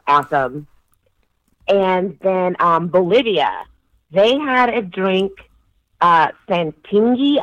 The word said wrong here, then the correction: Singani: I'm sure I'm mispronouncing it. – I got it. It's a Singani? – Singani. – Yes.